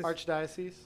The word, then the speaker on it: archdiocese: How do you say it? Archdiocese? – Archdiocese.